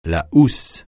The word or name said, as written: housse